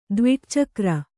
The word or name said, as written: ದ್ವಿಟ್ಚಕ್ರ